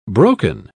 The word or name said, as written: broken